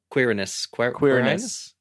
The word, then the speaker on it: Quirinus: Have you pronounced that name? – Quirinus. Yeah.